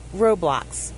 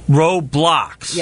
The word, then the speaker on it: Roblox: Roblox. – Roblox. – Yes. – How do you spell it? – R-O-B-L-O-X. – Okay. I keep thinking Roblox, but it's Roblox with an X.